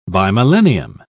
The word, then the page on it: bimillennium: British English